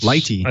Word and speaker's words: lighttpd: That's Lighty. I think is the official name for it.